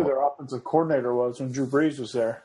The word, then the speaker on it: Drew Brees: I believe that's pronounced Drew Brees.